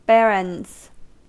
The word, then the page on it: barons: American English